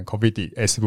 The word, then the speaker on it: Cofidis: 嗯，哦，发文可能 Cofidis 不发音哦。